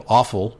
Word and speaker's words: offal: And so you eliminate that when you mix your muscle meat with what's called offal. It sounds awful, but it's spelled O-F-F-A. – O-F-F-A-L.